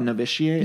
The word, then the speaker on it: Novitiate: You were in a movie called Novitiate.